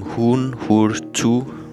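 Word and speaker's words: Huun-Huur-Tu: Og helt fonetisk udtalt hedder gruppen Hun Hur Tu.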